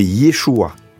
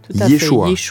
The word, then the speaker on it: Yeshua: Alors, c'est vrai qu'en français, on, le dit Jésus, mais en fait, en hébreu, c'est Yeshua. Yeshua.